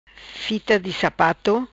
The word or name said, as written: Fita di Sapato